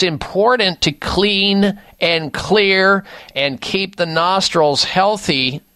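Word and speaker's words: Xlear: The one that I like at this time of year is called Clear Rescue Nasal Spray. Clear is spelled with an X, X-L-E-A-R. The X stands for xylitol.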